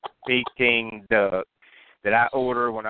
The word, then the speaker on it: Peking: Peking, like you're peeking into a box.